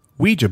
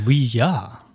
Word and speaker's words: Ouija: fact, it's actually a combination of the French and German words for yes. Ah, Ouija. So not really Ouija.